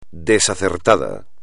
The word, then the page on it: desacertado: espagnol